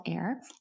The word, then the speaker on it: Aer: I call it the clit sucker, but it's spelt A-E-R. It's called Air.